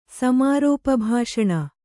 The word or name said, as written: ಸಮಾರೋಪಭಾಷಣ